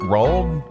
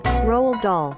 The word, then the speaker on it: Roald: Uh, how do you pronounce rolled? – Roald Dahl. – Roald Roald Dahl.